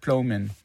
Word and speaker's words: Plomin: Ja, äh, bin selber nicht sicher, aber ich glaube, er sagt Plomin.